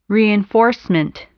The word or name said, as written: reinforcement